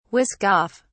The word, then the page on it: whisk off: British English